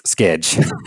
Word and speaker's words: Schedge: Yes, Schedge.